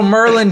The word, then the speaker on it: Maryland: And they, I don't know why I still remember that, but um, that's actually not that far from Pasadena, as uh, the locals say it uh, in uh, in Pasadena. Uh, little Maryland City, humor. Maryland. Little Merlin humor for uh, the boys and girls out there.